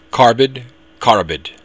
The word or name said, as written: carbad